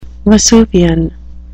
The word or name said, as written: vesuvian